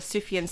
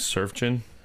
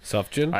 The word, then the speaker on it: Sufjan: Sufjan? – I actually wrote Surgeon Stevens. – Sufjan. The J is a Y sound.